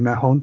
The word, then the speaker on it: Mahon: Mahon, it's one syllable. It's almost, pretend the O isn't there.